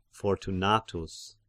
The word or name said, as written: Fortunatus